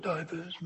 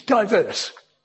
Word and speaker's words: diverse: It's not divers anyway, you know, into the water. It's diverse. Modern days we put an E on the end and put the accent on the second part, not divers, but diverse.